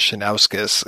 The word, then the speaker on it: Shinoukis: Well, my brother-in-law, his last name is Shinauskas, and the first time I ever met him, I was like, oh, is that S-H-I-N-O-U-K-I-S? And he's like, oh, yeah. I was like, oh, yeah, you just spell it the exact way that it sounds.